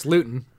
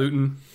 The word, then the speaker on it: Luton: Like, I know they had, you know, only 3 points on the day because they're the Jags, and Jake Luton, however you pronounce it. – It's Luton. – Yep. Luton.